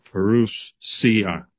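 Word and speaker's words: parousia: Yeah, Perusia, with the emphasis on Ru.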